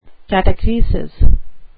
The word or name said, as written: catachresis